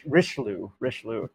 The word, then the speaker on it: Richelieu: And that's represented by this Cardinal Reclus, I believe his name is, or that's not how you say his name. I will forever mangle French names, because I'm awful at it. Richelieu, Cardinal Richelieu, who's basically thinks that this kind of bastion of tolerance is keeping the Catholic Church from fully, you know, fully enveloping France.